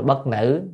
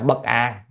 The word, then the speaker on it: Bất An: Thì có chủ thần tên là Bất An á, đáng lẽ hồi bữa nhìn sao không thấy cái bộ miên ở trên trên đọc là Bất Nữ, nhưng thật ra là Bất An. Có người nhắc cho là chữ này thì An, đúng rồi, về chữ thần Bất An nhưng mà Bất Nữ.